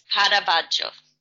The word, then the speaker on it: Caravaggio: Is that not pronounced Caravaggio? – No, it's not. It's Caravaggio.